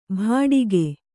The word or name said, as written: ಭಾಡಿಗೆ